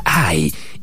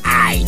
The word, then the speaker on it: Hai: El nombre de la ciudad se pronuncia Ai y se escribe H-A-I. – Oh, claro, la ciudad se llama Ai.